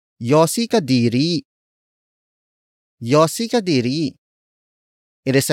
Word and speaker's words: yosi: Todits, which is spelled as T-O-D-I-T-S. Our last word for this lesson, yossi. Yossi. Yossi. Yossi is spelled as Y-O-S-I.